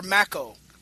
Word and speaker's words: Makow: Right, and then my name is Makow, Henry Makow. Rhymes with tobacco. – Oh, I'm sorry, sir. – No problem. – So, Dr. Makow.